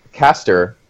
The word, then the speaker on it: Caster: But basically, it was the point that Caster Foe and the pronunciation is that Caster, like the C-A-S-T-E-R, the beginning of Caster Foe's name, is pronounced the same way as Caster, who like is a constellation, who is one of the twins, who is literally in space.